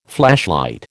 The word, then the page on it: flashlight: American English